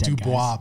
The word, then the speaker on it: Du Bois: Du Bois, which is spelled Du Bois, but everybody says Du Bois. It drives me nuts.